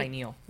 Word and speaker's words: pineal: Cleansing our pineal, did I say that right? – Pineal, yeah.